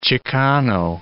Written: Chicano

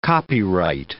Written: copyright

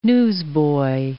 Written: newsboy

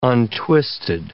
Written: untwisted

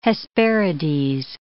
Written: Hesperides

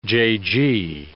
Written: jaygee

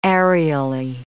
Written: aerially

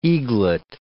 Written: eaglet

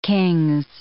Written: Kings

